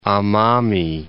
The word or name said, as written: Amami